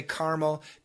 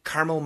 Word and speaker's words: caramel: Some people say caramel. Caramel macchiato. It's a really nice coffee. It's some espresso, lots of milk. Lots of caramel or caramel, both pronunciations we use them in America.